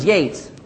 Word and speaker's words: Yeats: Yeats, how do you pronounce this? Yates. Yeats, but it's spelled Yeats.